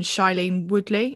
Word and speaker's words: Shailene Woodley: One of my films is The Mauritanian, and it's got Benedict Cumberbatch, Jodie Foster and Shailene Woodley.